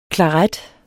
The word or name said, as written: klaret